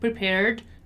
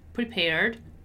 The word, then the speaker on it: prepared: Okay, so you don't, again, don't pronounce the E. It's just prepared, prepared, prepared.